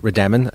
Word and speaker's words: Rademon: We pronounce it Rademon as if there's an A-N at the end, and it comes from the original Irish spelling for the townland, which would have been R-A-D-A-M-A-N.